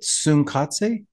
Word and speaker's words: Tsunkatse: I say Tsunkatse. Yes. Being of Chinese, being Chinese American and being able to speak Mandarin, there are a lot of TNS sounds that come out at the same time. Yeah. Tsunkatse is how I say it.